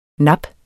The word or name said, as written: NAPPENE